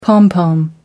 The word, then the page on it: pompom: American English